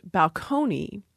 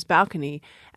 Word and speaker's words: balcony: And this has happened with, for example, the word balcony, which we borrowed in from Italian and was pronounced balcony. In the 19th century, people started to move the stress in that word up to the first syllable, so it was balcony. And I have some comments from the 19th century of people who felt that that pronunciation as balcony was just disgusting.